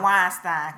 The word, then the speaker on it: Weinstein: Yeah, I'm going to call him Weinstein because it rhymes with Frankenstein.